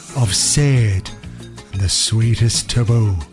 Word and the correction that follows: Sade: Sorry, my producer, Monsignor Murphy's in my ear saying it's pronounced Sade. So that's Sade with the Sweetest Taboo. You can listen to your Sade with a glass of lemonade.